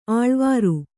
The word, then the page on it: ಅಳವರಿ: Kannada